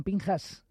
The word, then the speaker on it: Pinchas: Se escribe Pinchas, pero ellos pronuncian Pinchas, ¿eh?